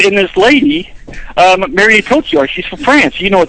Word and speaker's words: Cotillard: There's a woman in there, Marion Cotillard.